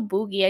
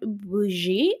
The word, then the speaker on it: bougie: Bro, I'm sorry I'm not cultured, but yeah, now I know that it's pronounced bougie, and it comes from the word bourgeois or the bourgeoisie.